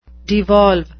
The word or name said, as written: devolve